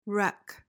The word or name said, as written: reck